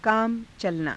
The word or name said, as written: kaama calanaa